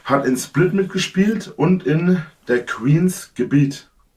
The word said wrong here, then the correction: The Queen's Gambit: The Queen's Gambit.